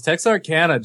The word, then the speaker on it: Texarkana: Texarkana or Arcana? How do you say that? – Oh man, not even close. Texarkana, John got it.